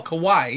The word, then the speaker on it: Kawhi: It's K-A-W-H-I. Kawhi, right? – Kawhi. – Kawhi? – Mm-hmm. – Oh, Kawhi, yes.